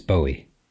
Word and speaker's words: Bowie: It's the new episode of the podcast where it's pronounced Bowie. That's correct, isn't it? There's a few different attempts to pronounce this person's name, but I've heard him say it, and it's Bowie.